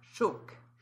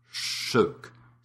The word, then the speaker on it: sjok: As in the word S-J-O-K is often used to illustrate it, which means chunk. And in your dialect of Swedish, you would pronounce that chok. – Shook.